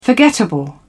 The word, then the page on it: forgettable: British English